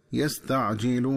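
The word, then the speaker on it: يستعجلونِ: وكذلك عند وصل آخر هذه الآية بالآية التي تليها ينبغي التنبه للنطق الصحيح للفظ يستعجلون، فيقرأ بكسر النون هكذا يستعجلوني.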